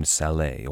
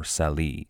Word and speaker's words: Salé: They arrived at a city named Salé, or Sali. The English pronounced it much like the name Sally.